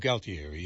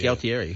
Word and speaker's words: Galtieri: G-A-L-T. – Oh, Galtieri. Yeah. – Galtieri.